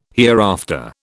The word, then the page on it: hereafter: British English